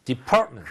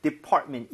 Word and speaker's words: department: Department. – 그렇죠. T가 어디 끼어 들어갈 수가 없어요, 지금, right? Department. 이래서 강세가 정말 중요한 겁니다.